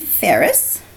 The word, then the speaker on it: Ferris: It's Beth Grzybinski Ferris.